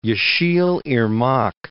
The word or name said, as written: Yesil Irmak